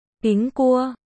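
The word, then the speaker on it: Pính cua (苹果): Trong tiếng Trung, quả táo được phát âm là Pính cua, đồng âm với chữ bình trong từ đêm bình an.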